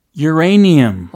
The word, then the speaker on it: uranium: Yeah uranium.